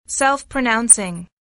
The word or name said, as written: self-pronouncing